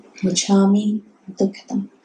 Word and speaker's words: Michammi Dukhadam: Michammi, M-I-C-H. I'll write it down somewhere on the way out. M-I-C-H-A-M-M-I, Michammi, Dukhadam, D-U-K-H-A-D-A-M. Michammi Dukhadam.